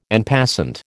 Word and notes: en passant: American English

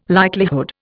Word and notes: likelihood: Indian

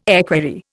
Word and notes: equerry: Indian

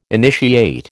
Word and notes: initiate: American English

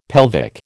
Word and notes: pelvic: American English

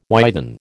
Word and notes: widen: American English

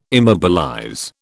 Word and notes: immobilize: British English

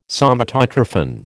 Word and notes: Somatotrophin: American English

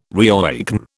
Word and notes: reawaken: British English

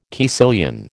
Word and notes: caecilian: American English

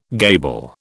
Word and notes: gable: British English

